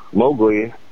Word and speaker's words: Mowgli: Yeah, I've never According to Elsie Kipling, Rudyard Kipling's daughter, Mowgli is actually supposed to be pronounced Mowgli, not Mowgli, and she reportedly never forgave Walt Disney for this gaffe.